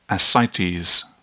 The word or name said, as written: ascites